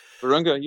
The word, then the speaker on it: Virunga: Don't know how to pronounce that. Virunga National Park. – Virunga, you had it.